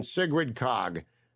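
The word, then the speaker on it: Sigrid Kaag: And I mean Sigrid Kog.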